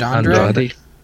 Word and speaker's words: Andrade: The Intercontinental Championship, Finn Balor versus, I hope I say this right, Andrade? – Andrade, yeah.